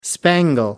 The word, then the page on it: spangle: American English